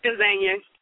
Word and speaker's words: Gazania: Now, am I pronouncing Gazana? Am I saying your name right? – It's Gazania.